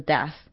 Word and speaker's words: death: even death 这边就是死亡，然后 death 的 th 是不发音的气音，可是还是要有那个就是空气从牙缝出来，就是 death 的那个就是气音。